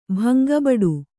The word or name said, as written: ಭಂಗಬಡು